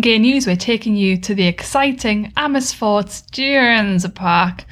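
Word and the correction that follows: Dierenpark: But sorry, I feel we need to like get a real true pronunciation of that. – Dieren Park. Zoo in the Netherlands.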